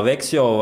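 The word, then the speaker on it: Växjö: Vietā, un Jānis Kalniņš viņa komandai labāk veic, Vekoā. Nu tā vismaz bija izruna, cita izrunā Vexio vai Lakers, nu labi, tas lai jāpaliek skatītāja rīcībā.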